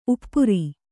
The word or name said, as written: ಉಪರಿ